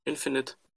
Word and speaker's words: Infinite: Wie wird es ausgesprochen? Infinite. Danke. Infinite Frontier